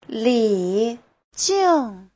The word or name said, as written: li3jing4